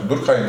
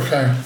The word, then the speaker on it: Durkheim: Durkheim da kendince bu böyle mi okunuyor hocam? Durkheim diye okunuyor. Durkheim.